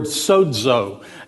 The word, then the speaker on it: sozo: The Greek word for saved is the word sozo. It's S-O-Z-O, and it's pronounced S-O-E-D, sozo.